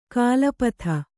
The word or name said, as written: ಕೊಲೆಪಾತ